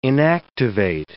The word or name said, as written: inactivate